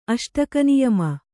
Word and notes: ಅಷ್ಟಕನಿಯಮ: kannada

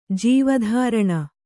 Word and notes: ಜೀವಧಾರಣ: Kannada

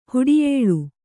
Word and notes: ಹೊಟ್ಟೆಯಿಳಿ: hoṭṭeyiḷi

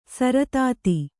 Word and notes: ಸರತಾತಿ: saratāti

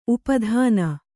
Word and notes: ಉಪಾದಾನ: upādāna

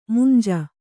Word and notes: ಮೌಂಜಿ: maunji; Kannada

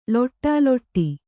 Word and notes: ಲೊಡಲೊಡ: loḍa loḍa

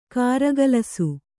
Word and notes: ಕಾರಗಲಿಸು: kāragalisu

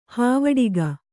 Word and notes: ಹೂವಡಿಗ: hūvaḍiga

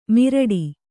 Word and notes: ಮಿರಡಿ: kannada

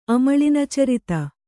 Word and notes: ಅಮಳಿನಚರಿತ: amaḷinacarita

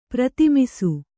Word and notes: ಪ್ರತಿಮಿಸು: Kannada

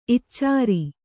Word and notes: ಇಚ್ಚಾರಿ: iccāri

